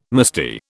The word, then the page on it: musty: British English